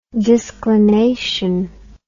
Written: disclination

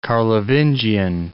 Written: Carlovingian

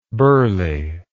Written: burghley

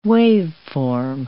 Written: waveform

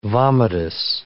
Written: vomitus

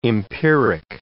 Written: empiric

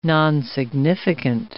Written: nonsignificant

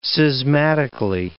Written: schismatically

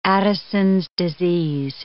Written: Addison's disease

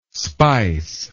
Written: speiss

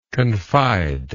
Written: confider

